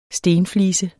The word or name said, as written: stenflise